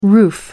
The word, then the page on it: roof: American English